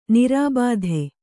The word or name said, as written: ನಿರಾಬಾಧೆ